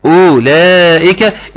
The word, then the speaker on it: أولئك: طيب ليه الواو ده ما بتنطقش؟ لانها لو نطقت هتكون ايه هتكون اولئك. خدت بالك من من النطق ده اولئك وانا بقولش كده او ما انا بقول ايه اولى بنتقل من الهمزة المضمومة الى اللام المفتوحة مباشرة.